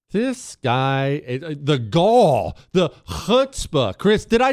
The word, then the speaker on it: chutzpah: How do you say it? You say it for me. Chutzpah. Back of the throat? Chutzpah.